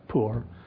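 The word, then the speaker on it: poor (פור): So again, that casting, it was called P-U-R, pronounced poor.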